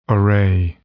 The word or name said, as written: array